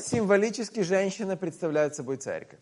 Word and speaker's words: церковь: Церковь. – Huh? – Церковь. – Церковь. – Церковь. – That's too hard to pronounce. – Yeah, церковь. Like TS, церковь.